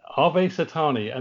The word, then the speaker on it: Ave Satani: Is the cue called Ave Sentieni? Is that how it's pronounced? – Ha. Ave Satani.